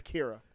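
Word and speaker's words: Qi'ra: Weird, but it's Kira.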